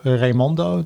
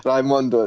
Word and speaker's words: Raimondo: And so, I'm here with Michael Raimondo. Do I pronounce that correctly, or? – That's perfect. Raimondo, that's great. – Yes. Raimondo, where did that name come from originally? – It's an Italian surname.